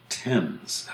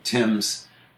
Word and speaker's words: Thames: One of the ones that really grates on me is the famous river that runs through London is called the Thames, of course, a not very intuitive spelling of T-H-A-M-E-S, rhyming with rhyme with anything. Anyway, the Thames River, Connecticut, is the Thames.